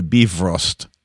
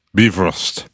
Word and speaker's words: Bifrost: Although it was not the F sound. They kind of went the Bifrost. – Bifrost.